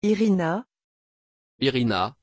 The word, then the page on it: iryna: French